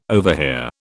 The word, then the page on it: overhear: British English